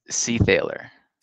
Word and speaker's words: Seethaler: It's Seethaler.